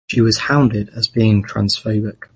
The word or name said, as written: hounded